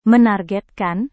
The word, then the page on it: menargetkan: Indonesian